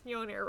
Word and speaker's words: Mjolnir: I was like, it's Mjolnir, right? – Yeah.